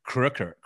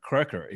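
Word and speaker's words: Kroeker: So the real spelling should be K-O-N-I-G with the O with the two dots. So Croker or Craker's last name should be K-R-O with the umlaut, K-E-R without the E. The E is just to symbolize that there should be an umlaut over the O. So the way you pronounce, for instance, Walter Koenig is really Koenig. Koenig in German. So er is the sound of the OE. Yeah. So it should be Kröker. Kröker is what it should be.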